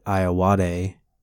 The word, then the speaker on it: Ayoade: Another thing I watched that was incredibly cheeky because it stars one of our favorites, Richard Ayoade. Did I say it right? – Yeah. – Yes.